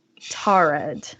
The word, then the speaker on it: Taured: Tarid. And it's spelled T-A-U-R-E-D.